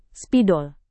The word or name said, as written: spidol